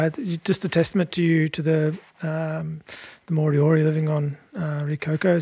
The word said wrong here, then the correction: Rēkohu: Did I pronounce that right? Rikoko? – Rikoku. – Rikoku. I'm going to stuff this. I'm so sorry. – It's K-O-H-U, Rikoku.